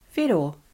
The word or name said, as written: whero